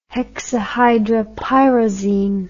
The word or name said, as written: hexahydropyrazine